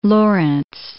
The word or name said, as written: Lorentz